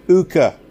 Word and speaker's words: Hooker: Mr. Brooker had a queer way of pronouncing his name, without the H and with a long U. Ooker.